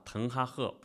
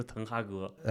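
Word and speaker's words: Ten Hag: 伦是不太像的，咱们好像每年都因为曼联的球员去纠正发音，搞得滕哈赫自己都说是啊滕哈赫，不是滕哈格。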